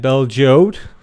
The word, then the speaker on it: Bel Geode: And Bel Geode.